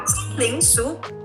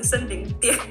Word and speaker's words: 森林塾: 是的，天地有大美，整个大自然都是我们的教室啊，所以我们说叫森林塾，哦，就是哎、欸，很多时候的大家会念成森林殿，然后我们就要再解释，哦，是塾，是塾的意思。